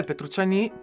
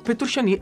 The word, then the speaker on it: Petrucciani: E eh, Michel Petrucciani, Petrucciani perché... Ma anche Petrucciani. – Anche eh, Petrucciani? Sì, sì, c'è anche la pronuncia italiana.